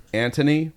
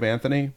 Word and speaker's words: Anthony: Did it piss you off that Laura kept pronouncing her name Antony instead of Anthony? – I didn't notice.